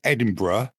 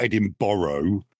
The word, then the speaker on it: Edinburgh: First of all, it's Edinburgh, not Edinburgh. I can't stand the way that's pronounced in an American accent.